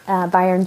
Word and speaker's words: Bayern: I said it wrong before, not Bern. Bayern, Germany.